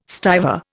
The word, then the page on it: stiver: Indian